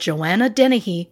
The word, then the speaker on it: Dennehy: I'm saying that correctly? – You are. – This is what Lauren has to say. Over 10 days in 2013, 31-year-old Joanna Dennehy went on a killing spree, leaving 3 men dead and 2 badly wounded.